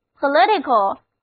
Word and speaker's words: political: 现在我们在表示政治的 politics 后面加上 al 的形容词后缀，那么 political 注意重音后移了，单词 political 就读作 political，意思就是政治的，政党的。